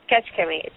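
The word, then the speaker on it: Kecskemét: No, it's spelled K-E-C. – K-E-C. Mm-hmm. – S. – Mm-hmm. – K-E-M. E-T. – How do you pronounce that? – Ketchkemit. – It says Ketchkemit.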